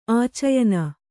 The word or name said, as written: ಆಚಯನ